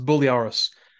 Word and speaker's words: Briareos: That's it's it's spelled like Briareos, but it's it's pronounced Buliaros he has a long Greek sounding last name.